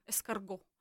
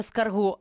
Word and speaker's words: ескарго: Ескарго. Ну, не правильно ставити наголос на останній склад.